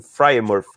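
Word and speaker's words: Freiermuth: E o meu primeiro é o Pat Frymouth, como é que se pronuncia certinho? Fryermuth, né? Fra Fryermuth. Fryermuth, de Penn State. Quase igual.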